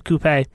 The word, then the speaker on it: coupe: Just keep looking for that seafoam green 1953 Chevy 150 Club Coupe. – We're in America. It's a coupe. – Rhymes with toupee.